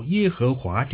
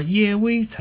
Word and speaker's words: 耶威: 看来呢一般比较接受耶和华这个发音，它可以追溯到 16 世纪。其实呢耶威才是神名字的正确发音。